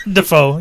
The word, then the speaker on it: Dafoe: Dafoe.